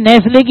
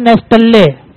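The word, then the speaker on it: Nestlé: ویسے بھی نیسلے کی اسپیلنگ میں آتا ہے نیسٹلے, ہاں ٹی سائلنٹ ہے نا بھائی, صحیح ہے جی, مزمر ہے چھپا ہوا ہے, ہاں لکھا ہوا ہے پڑھتے نہیں ہے نیسٹلے, صحیح ہے جی, تو بھائی اپنے راستے پہ نکل لے, نیسٹل لے اپنے راستے پر نکل لے, ہم نے اہتمام کرنا ہے.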